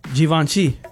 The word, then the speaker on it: Givenchy: Givenchy.